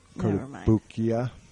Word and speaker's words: Kotobukiya: And it's mainly because I don't have one of the, I don't have any Kotobukiya. I think that's how you pronounce it. Kotobukiya. – Kotobukiya.